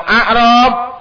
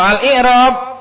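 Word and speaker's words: al-i'rab (الإعراب): Qala fa ammal i'rabu, adapun i'rab, fa huwa bi kasril hamzah, maka bacanya itu dengan hamzahnya di kasroh, i bukan a. Karena kalau a'rab itu jamak dari arabi, orang badu, al-a'rab. Tapi kalau al-i'rab artinya penjelasan.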